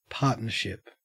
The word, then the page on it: partnership: Australian English